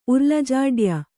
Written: ಉರ್ಲಜಾಡ್ಯ

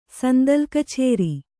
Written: ಸಂದಲ್‍ಕಛೇರಿ

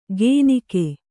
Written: ಗೇನಿಕೆ

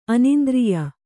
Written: ಅನಿಂದ್ರಿಯ

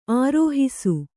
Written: ಆರೋಹಿಸು

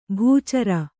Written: ಬೆಚ್ಚರ

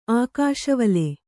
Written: ಆಕಾಶವಲೆ

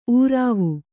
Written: ಊರಾವು

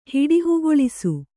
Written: ಹಿಡಿಹುಗೊಳಿಸು

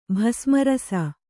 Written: ಭಸ್ಮರಸ

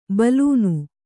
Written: ಬೆಲೂನು

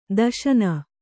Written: ದೇಶನ

